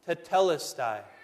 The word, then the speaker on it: tetelestai: No sooner had the sponge containing that sour wine been taken away from Jesus' lips, but he shouted with a loud voice, one Greek word, four simple syllables, tetelestai. It is finished.